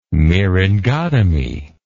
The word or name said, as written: myringotomy